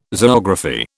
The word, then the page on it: zoography: British English